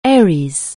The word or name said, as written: Aries